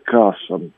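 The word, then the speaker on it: Кассам: Это по поводу палестинских ракет «Касом». Правильное ударение на первый слог. Там звук «К», который передается буквой «Q». Значит, в латинском воспроизведении.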